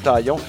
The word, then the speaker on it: Taillon: Et lorsque Taillon était avec les Yankees, tu sais, c'est avec le N, on prononce toutes les consonnes ou à peu près en anglais, mais dans le cas de Jameson Taillon, il le prononçait de façon impeccable. Comme s'il était un francophone, Taillon.